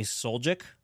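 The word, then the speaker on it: Suljic: Apparently you said we have Sonny Soljak. Soljak is how you say his name? – Yeah.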